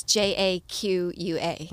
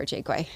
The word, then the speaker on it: Jaqua: And so my sister who lives in California, she will pronounce it the French way, Jaqua but I prefer Jaquay.